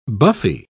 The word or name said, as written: buffy